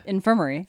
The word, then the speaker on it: infirmary: You're putting the wrong emphasis on the wrong syllable. – One of the nurses in the... – Infirmary.